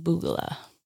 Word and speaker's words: B00GALAH: That is pronounced Boogala.